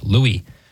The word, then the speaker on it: Louis: I try and go with the French pronunciation, of course, King Saint Louis IX. But sometimes when I see it in isolation, just as a name, I think, oh, Louis, right?